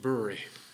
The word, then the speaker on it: bury: Bury is spelled B-U-R-Y, and I always want to pronounce it Bury. And I did that with my wife, and she kept saying, it's pronounced Bury. It's pronounced Bury. I said, no, it looks like Bury to me. So I looked it up in the dictionary. Sure enough, it was Bury, and I never told Kathy.